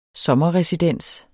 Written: sommerresidens

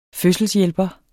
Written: fødselshjælper

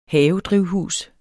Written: havedrivhus

